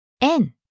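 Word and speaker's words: En (円): But how do the Japanese call it? – En. It's spelled E-N in Romaji.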